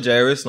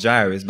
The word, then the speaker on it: Jairus: Yeah, but I kind of like my name because I don't know any other Jairus, number one. And my name actually means the light from God. My name is biblical. Pronunciation is not biblical. Biblically pronounced as Jairus, but my name is God's enlightenment.